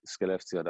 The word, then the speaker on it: Skellefteå: You might have to help me on the pronunciation of this. Was it Skellefteå? – Skellefteå. That's the team.